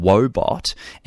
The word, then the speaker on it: Woebot: It's called Wobot, and it's spelled Woebot, and you can download it from your good app store.